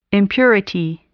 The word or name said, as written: impurity